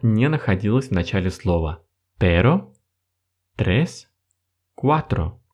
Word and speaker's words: pero: Есть очень похожие два слова – pero и perro. Казалось бы, мы всего лишь усилили немного произношение буквы R, однако смысл полностью поменялся. Если pero это слово означает но, то тогда perro означает собака.